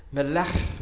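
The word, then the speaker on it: Melech (מלך): So Lamed, Kaf, and Mem make the word king, and the meaning king, and king is pronounced as Melech. Hours of training.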